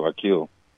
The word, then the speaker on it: Akil: changed my name to Akil. Went by Akil, my regular name. – That's beautiful. – I spell it differently, though. A-K-I-L.